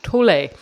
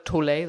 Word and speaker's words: Tolle: But today we are going to be talking about The Power of Now by Eckhart Tolle. And I believe uh, that is how you pronounce his surname because I looked it up, whereas you might read it and think it's Toll, but it's Tolle.